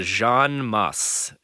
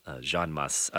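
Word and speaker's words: Jeanne Mas: Jean Mass. It's okay. J-E-A-N-N-E. And the last name's M-A-S, Mass. Jean Mass.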